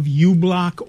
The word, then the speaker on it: uBlock: I'm a fan of uBlock Origin, and it's spelled like it sounds. The letter U, the word block.